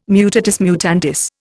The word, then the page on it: mutatis mutandis: Indian